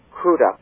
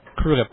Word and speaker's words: Crudup: Billy Crudup was wonderful, or I believe he's called Crudup. – Crudup, yeah. – Crudup, yes, because he thanked the presenter for pronouncing his name properly.